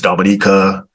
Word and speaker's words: Dominica: Like I keep saying, it's not Saint Lucia, it's Saint Lucia, it's Grenada, uh, and, and, you know it's Dominica, so they they can't find it.